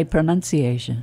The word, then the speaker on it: pronunciation: Ironically, many people mispronounce this word. It's that sneaky dropping of the O from the word that's confusing. It's pronunciate. Pronunciation. – Yay. – Not pronunciation.